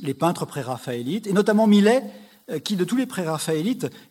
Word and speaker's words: Millet: Et il se trouve dans les dessins de Tissot, en Angleterre, un dessin qu'il a dédié au peintre Millet, non pas notre Jean-François Millet à nous, d'ailleurs qu'il faut prononcer Millet, mais Millet, M-I-L-A-I-S.